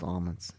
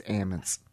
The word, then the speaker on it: almonds: If you look it up in the dictionary, it's not pronounced almonds. It's ammons.